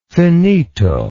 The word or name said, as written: finito